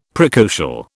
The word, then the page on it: precocial: British English